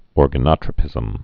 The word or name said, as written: organotropy